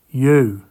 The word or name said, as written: Yow